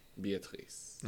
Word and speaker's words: Beatrice: Beatrice, I think is what I was calling her. And I was like putting emphasis in the wrong syllable. Emphasis in the wrong syllable. – Because it's a French name. Beatrice.